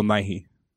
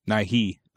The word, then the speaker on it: Nighy: That's how you say his name. Bill Nighy. – Bill Nighy? – Nighy.